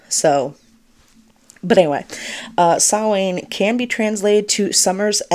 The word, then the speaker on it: Samhain: The word Samhain itself, which is pronounced Samhain or Samhain, not Samhain.